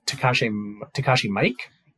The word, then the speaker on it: Takashi Miike: But this is a movie directed by, I believe it's pronounced Takashi Miike.